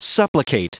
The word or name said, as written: supplicate